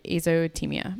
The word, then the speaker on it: azotemia: And are you pronouncing it correctly? Azotemia? – You mean, are you pronouncing it correctly? Yes, I believe that is how you... Azotemia.